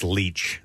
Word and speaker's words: Leitch: So I guess it's pronounced Leitch, spelled L-E-I-T-C-H.